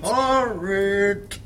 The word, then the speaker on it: horrid: Horrid! – Comment? – Horrid, avec un H. Il faut prononcer les H.